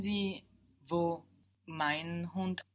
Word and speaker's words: Hund: But in German, the everyday word for dog is Hund, hound.